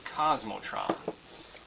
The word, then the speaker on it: Cosmotrons: So the name of the game that we have created and we're still in development on is called Cosmotrons. You got to say it like that. Cosmotrons.